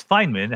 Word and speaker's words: Feynman: Yeah, it's Feynman, how he pronounced it.